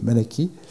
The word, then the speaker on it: Malachie: Et je vais essayer ce matin de démarrer sur le premier discours de Malachie, je vais parler Malachie, parce que c'est le nom tel qu'on le prononce dans la Bible.